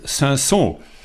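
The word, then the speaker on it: Cinsault: Also die Eltern sozusagen sind Pinot Noir oder auch Spätburgunder, wie man in Deutschland sagt, und Saint-Saëns. Der schreibt sich anders, wie ich ihn jetzt ausgesprochen habe, aber er ist auch bekannt, als Hermitage und ja, Pinot Noir und Hermitage und daraus wird dann Pinotage.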